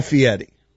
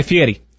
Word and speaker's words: Fieri: How do you spell his last name? – F-I-E-R-I. The bet should include... – How is that Fieri? That's Fieri. – It's the way he pronounces it.